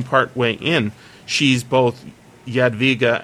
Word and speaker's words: Jadwiga: An underground passage, thought Queen Jadwiga. I guess is that how you pronounce it? – Jadwiga. Sure.